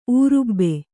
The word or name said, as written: ಊರುಬ್ಬೆ